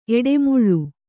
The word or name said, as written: ಎಡೆಮುಳ್ಳು